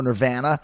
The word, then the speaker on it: Nirvana: Or Nirvana, if you're from Canada. Cobain used to point that out, that Canada was the only country in the world who pronounced the name of the band Nirvana. Drama, pasta, ante. That's how we talk.